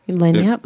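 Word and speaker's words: Lagniappe: When I did my Lanyap interview, am I saying that right, Lanyap? – Lanyap. – Lanyap mm-hmm.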